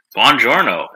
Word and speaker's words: Buongiorno: Buongiorno, in the words of Brad Pitt. that is the correct pronunciation.